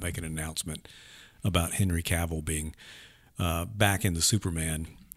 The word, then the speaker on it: Cavill: The Henry Cavill, rhymes with travel, news that I had, the rumors turned out to be unfounded.